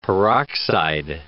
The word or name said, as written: peroxide